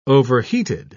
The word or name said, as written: overheated